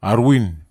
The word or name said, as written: arwin